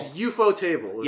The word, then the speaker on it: Ufotable: It's Ufotable is the official pronunciation.